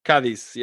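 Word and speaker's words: Cádiz: Cadiz, yeah.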